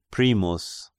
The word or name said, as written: Primus